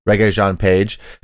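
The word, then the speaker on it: Regé-Jean Page: Reggae Jean Page.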